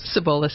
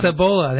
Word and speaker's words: Cibola: So say it correctly again for us. Cibola Systems. Cibola. There we go.